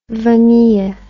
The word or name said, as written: vanille